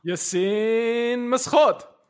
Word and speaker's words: Yassine Meskhout: Yassine Meskhout.